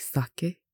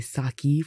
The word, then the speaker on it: sake: And also in Versace, as people say, I don't know, Westerners have this weird obsession with overpronouncing E's. So like in Japanese, their wine is like sake, and people say sake for some reason.